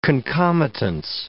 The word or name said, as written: concomitance